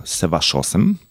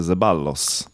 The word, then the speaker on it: Ceballos: Nasz najlepszy deblista Łukasz Kubot w parze z Argentyńczykiem Horacio Ceballosem. Tu taka ciekawostka. Jak byśmy czytali po polsku, to będzie Zeballos. Obok mnie siedzi Argentyńczyk. Spytałem się, jak wymawia się to nazwisko i wymawia się zupełnie inaczej. Ceballos.